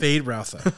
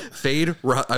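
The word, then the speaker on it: Feyd-Rautha: His character is the nephew of the Baron, Foyd Rotha. Am I saying that right? – Fade Rotha. Yeah. – Fade Rotha. I wasn't saying it right.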